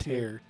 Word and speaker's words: Tyr: And so the album Tear... Or tier. – Tear, I think it's pronounced Tear, T-Y-R.